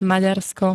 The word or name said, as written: Maďarsko